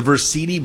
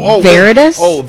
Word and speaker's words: Versiti: V-E-R-S-I-T-I. Versiti Blood Center today from 1 till. – Veritas? – No, it's Verse. It's V-E-R-S-I-T.